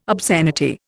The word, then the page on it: obscenity: Indian